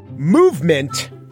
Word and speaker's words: MVMT: Movement, which is spelled MVMT, but is pronounced movement.